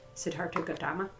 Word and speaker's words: Siddhartha Gautama: Siddhartha Gautama.